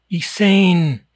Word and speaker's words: Isaan: It's spelled, in English, it's spelled I-S-A-A-N, Isan. And you get these guys come down, mostly Brits, and it's they'll pronounce it every possible, Isan, Isan, Isane. You know, no, it's Isan.